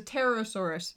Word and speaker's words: pterosaur: So they published an article in there about, I think it's called a Petrosaurus. No, it's a pterosaurus. Sorry, pet. – Yeah, you never pronounce those P's.